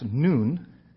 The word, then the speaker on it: nun: So there's kairos, there's chronos, and there's a third word in the Bible related to time, and it's pronounced noon. If you want to say that, it would be N-U-N, sort of. Noon.